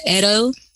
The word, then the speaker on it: Edo: In further pursuit of her advocacy and social justice, she joined the Edo, is that pronounced correctly? – Yes. – Edo State Hospital's Management Board and made history by establishing the first OT department in Nigeria within a state hospital.